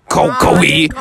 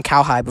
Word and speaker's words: Kawhi: Okay, so basically, if you're watching the NBA Finals game too, basically, Kawhi. – I called him Cowhide before.